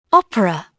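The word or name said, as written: opera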